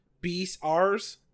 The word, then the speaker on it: Beastars: How do you say the word? Because it's B-E-A-S-T-A-R-S. Beastars? – I call it Beastars.